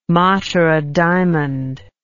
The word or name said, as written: Matara diamond